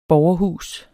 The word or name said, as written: borgerhus